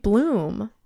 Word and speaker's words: Blum: Blum.